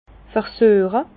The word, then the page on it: versüeche: Bas-Rhin; Herrlisheim